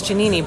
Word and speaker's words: Giannini: I know it's Giannini, but it's so Italian.